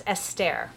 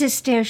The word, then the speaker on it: Esther: Tell us about your recent journey with I keep saying that wrong. It's Esther. – It's Esther. She's Belgian. She's born in Belgium. And so I guess it's the French pronunciation of what we would recognize as Esther. But she says Esther.